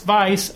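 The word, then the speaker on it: Weisz: And stay classy, Rachel Vice. And it is Vice, because I looked up an interview with her, and she said, it's Vice, as in Vice.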